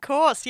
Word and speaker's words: course: And it was because of you that I became aware, like, no, you pronounce them differently. – It's curse and course, you idiot.